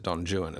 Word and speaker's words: Don Juan: His poem Don Juan, and he makes you pronounce it Don Juan as well, not Juan, because he rhymes it with new one and true one.